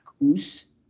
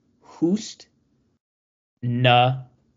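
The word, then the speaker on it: Khusnutdinov: It's a silent K. – Oh, who's... – So it's not you? It's not Koos. It's Hoost. Nadinov. Hoost Nadinov is, I believe, how it's pronounced.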